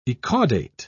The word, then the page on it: ecaudate: British English